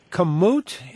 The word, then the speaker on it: Kamut: And that reminded me of another delicious and heavy grain to use, healthy grains to use, a kamut. Is that the proper way to pronounce it? – Kamut, yes, the camel's tooth.